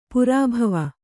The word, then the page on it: ಪೆರ್ಬಾವು: perbāvu